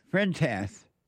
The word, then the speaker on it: Fuentes: Vice is reported that Nick Fuente, am I saying his name right? – Fuente. – Nick, no, I'm horrible at his last name, even when I had him on the show. But Nick Fuente. – Fuentes.